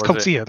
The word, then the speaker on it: Coetzee: Like, I think it's Coetzee. That's how you pronounce the name.